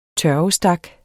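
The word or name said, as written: tørvestak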